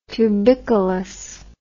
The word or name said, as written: tubicolous